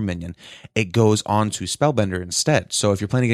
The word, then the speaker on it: Spellbender: I thought Spellbinder was a minion. – Mage secrets. Yeah, I might be saying it wrong. It's... Because there's Spellbinder, there's Counterspell. It's Spellbender.